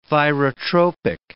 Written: thyrotropic

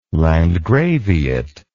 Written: landgraviate

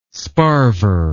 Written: sparver

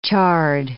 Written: chard

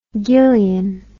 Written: gillion

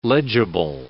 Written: legible

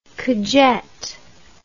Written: cujette

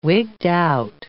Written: wigged-out

